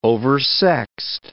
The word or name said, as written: oversexed